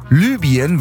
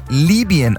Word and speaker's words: Libyen: Libyen wird korrekt ungefähr Libyen ausgesprochen, nicht Libyen, wie ich gerade eben.